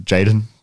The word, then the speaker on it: JXDN: professionally known as JXDN, pronounced Jaden. There you go. – Oh, so you still pronounce it as Jaden.